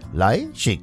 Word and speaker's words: Leischig: Julia Leischig, Leischig, so wird sie ausgesprochen.